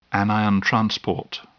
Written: anion transport

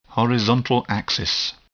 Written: horizontal axis

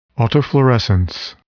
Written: autofluorescence

